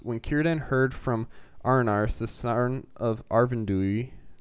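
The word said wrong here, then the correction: Arvedui: did I say that right? – Arvidua. – Arvidua.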